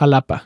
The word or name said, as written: Xalapa